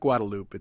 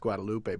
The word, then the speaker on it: Guadalupe: You know, Now, if your name is Guadalupe, right, and many years ago, you start telling people, listen, it's not Guadalupe, it's Guadalupe, but they still call you Guadalupe. Well, you know, after years go by, you get tired of correcting people, mm-hmm. or just telling them how to pronounce it in Spanish.